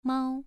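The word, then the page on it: mao: tone 2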